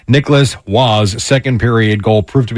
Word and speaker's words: Roy: No relation to Patrick Roy, which I did look up because I was curious, uh, and that is how you pronounce his name.